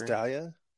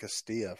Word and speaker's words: Castalia: It's Castalia? I've been saying that Castilla for my entire life. I did not know that. Mm. Oh, I pronounced that wrong.